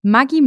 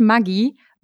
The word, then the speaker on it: Maggi: Maggi, also Julius Maggi, ist Italiener und daher spricht man eigentlich Maggi Maggi aus.